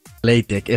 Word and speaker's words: LaTeX: ¿Cómo se pronunciaba? – Se pronuncia LaTeX. – LaTeX, eso, es verdad, LaTeX.